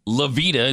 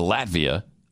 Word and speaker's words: Latvia: Lavita instead of Latvia.